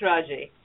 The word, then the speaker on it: Raji: Is it, should I drop the E or is the E pronounced? – Say it again, Pat. – Is it Raj or Raji? – It's Raji.